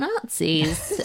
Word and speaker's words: nazi: No, well, it's just a sort of strange coincidence that the word for coconut in Swahili is spelled N-A-Z-I, although it's pronounced Nazi, just like Winston Churchill used to say, we shall fight them on the beaches, these dreadful Nazis.